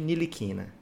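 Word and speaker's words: Ntilikina: Aprendi a pronúncia americana. Provavelmente é a mais errada de todas, né? Que os americanos gostam de errar, Guilherme. Mas eles omitem o T e fica Frank Nilikina.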